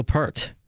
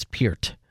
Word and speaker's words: Peart: and a lot of people I mean would say Neil Peart. No, it's it's Peart.